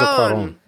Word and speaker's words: Cuarón: Alfonso Cuarón.